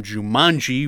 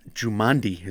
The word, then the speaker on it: Jumanji: Karen Gillan from Jumanji, which I mispronounced last time. Welcome to the jungle. – You mispronounced Jumanji? – Jumandi is what I said.